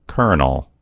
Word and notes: kernel: American English

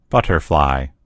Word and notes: butterfly: American English

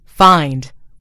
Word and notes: find: American English

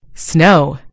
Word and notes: snow: American English

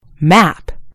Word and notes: map: British English

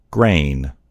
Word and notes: grain: American English

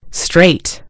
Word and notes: straight: British English

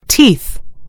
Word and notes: teeth: British English

